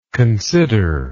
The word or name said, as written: considerer